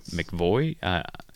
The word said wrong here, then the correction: McAvoy: McAvoy. – McAvoy. That's why there's an extra syllable.